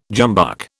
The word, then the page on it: jumbuck: British English